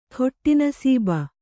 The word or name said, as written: ಘಟನಾಸಭೆ